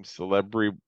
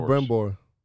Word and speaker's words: Celebrimbor: Celebrim, whatever. The elf that wants to build the towers. – Celebrimbor.